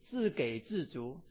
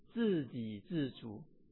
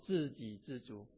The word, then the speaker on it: 自给自足: 我去搜索这个成语的时候，我读的时候有人，你们会笑我啊，我会读自给自足，错啊，这是汉阳牧师的程度。正确的发音是自给自足啊，自给自足啊，基本上英文是 self-sufficient, self-satisfying。